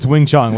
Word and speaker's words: Wing Chun: Wing Chun, it's not lying, it's fine. In English, it's Wing Chun. Whatever the proper one is, I don't know, but that's what the English version.